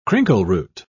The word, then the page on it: crinkleroot: British English